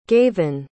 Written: gavan

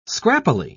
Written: scrappily

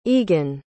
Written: egan

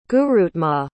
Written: gurutmat

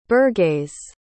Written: burgeis

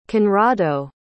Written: conrado

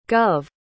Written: gov

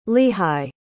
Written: lehigh

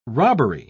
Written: robbery

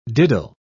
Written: diddle

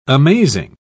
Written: amazing

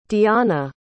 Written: deonna

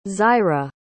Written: Zaira

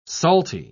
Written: salty

